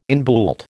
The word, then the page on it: inbuilt: American English